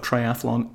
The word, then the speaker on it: triathlon: It is not triathlon. Why do people insist on putting the extra vowel sound in there?